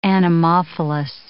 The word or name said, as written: anemophilous